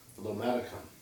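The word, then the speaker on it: lomatium: Some examples of this would be lomation, or sometimes it's pronounced lomaticum.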